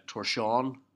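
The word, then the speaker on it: Tórshavn: Not that I don't trust Adrian Kerr, but everything I've heard has been Torshavon, so that's the English pronunciation. He's all about the Faroese pronunciation.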